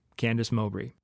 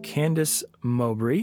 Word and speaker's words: Candice Mowbray: And now I bring you Candice Mowbray. I am here with Candice Mowbray.